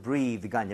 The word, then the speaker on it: breath: 발음도 breathe가 아니라 breath. – breath. 이거 두 개는 주의하셔야 되겠죠?